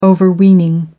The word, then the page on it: overweening: American English